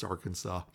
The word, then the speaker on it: Arkansas: And it's actually written in their constitution. It's pronounced Arkansas.